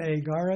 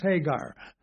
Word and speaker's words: Hagar: In Greek, if you have a rough breathing in front of a vowel, it gives that vowel an H sound. So it's not Agar, it's Hagar. If you have a smooth breathing, that's one that curves the opposite way, then you'd pronounce the vowel.